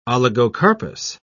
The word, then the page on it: oligocarpous: British English